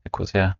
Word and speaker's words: Ecosia: There was a person, RT, who were doing this with you, and then he connected you with people from Ecosia. Did I pronounce it correctly? Ecosia, the company. – Yes.